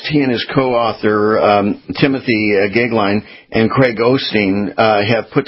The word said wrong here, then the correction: Austin: Is it Osteen or Austin? – Austin, and good morning.